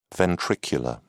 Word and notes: ventricular: British English

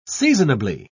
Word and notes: seasonably: British English

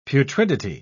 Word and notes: putridity: British English

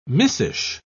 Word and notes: missish: British English

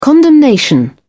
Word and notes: condemnation: British English

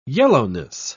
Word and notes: yellowness: British English

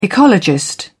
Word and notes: ecologist: British English

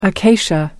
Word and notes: acacia: American English

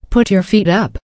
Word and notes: put your feet up: American English

